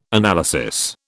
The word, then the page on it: analysis: British English